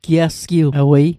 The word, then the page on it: giasgiwewei: Mi'gmaq